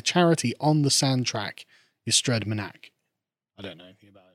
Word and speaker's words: Ystrad Mynach: Do Voice of Wales know about the recent attack by a charity on the sand track Ystrad Mynach? I can't pronounce that. – Ystrad Mynach, is it? – Yeah, it's Ystrad Mynach.